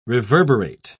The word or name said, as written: reverberate